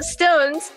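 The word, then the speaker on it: SixTONES: Yeah, so Stones, you spell it six tones and it's just like one word together, and then the tones part is all capitalized.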